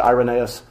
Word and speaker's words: Irenaeus: Yeah, Irenaeus or Irenaeus. Yeah, I always pronounce it Irenaeus.